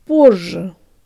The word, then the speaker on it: позже: The ye in the second syllable actually sounds like a schwa, and remember to lengthen the z a little. Z. Z. Z. Now the whole thing. Jaw tongue lips. Позже.